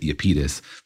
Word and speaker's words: Iapetus: According to my internet Googling, that is the correct pronunciation of this solar system body, which originally comes from the Greek. – I see. And if it's on the internet, it must be true. – Exactly. I also found people from New Jersey who pronounced it Iapetus, but I think we should go with the Greek version.